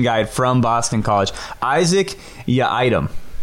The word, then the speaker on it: Yiadom: No, like you're leaving the supermarket and like, can't you forgot your item? Like, that's how you say it. Your item. – Not your item. Not my item.